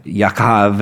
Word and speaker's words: Yaakov: And after this, his brother came out with his hand grasping at Esau's heel, so he was named Jacob or Jachav or Yaakov. If you go to Israel, this is a common name, and that's kind of how they pronounce it.